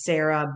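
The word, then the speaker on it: Sara: It's Sarah B. Stern, S-A-R-A. I don't have that silent H. The letter B, Stern, S-T-E-R-N.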